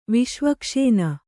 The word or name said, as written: ವಿಷ್ವಕ್ಷೇನ